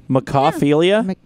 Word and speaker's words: mechaphilia: I can't even pronounce this. Mechaphilia? – Mecha? Like Mecha Godzilla? – No, it's M-E-C-H-A-P-H-I-L-I-A. – Like Mecha Streiskan? – A-P-H-I-L-I-A. – Mechaphilia? – Yeah. – Mechaphilia. Yeah, like Mecha Streisand.